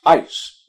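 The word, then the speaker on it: eis (εἰς): It is pronounced eis, E-I-S.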